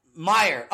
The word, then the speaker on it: Meyer: And for this episode, Carl Mayer, and I hope I said that right. Meyer. Oh, I did. Fuck, I said Mayer again. And I even read it. I'm like, I'm going to say it right. I'm going to say it right. And I still said it wrong. I still. Carl Meyer.